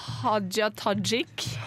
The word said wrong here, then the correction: Hadia Tajik: kanskje, kanskje Solveig som har vært i Israel kan ha bedre mulighet for å uttale det? – Hadia Tajik.